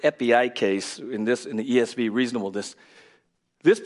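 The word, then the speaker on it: epieikes (ἐπιεικής): The Greek word for reasonableness there is pronounced epieikes.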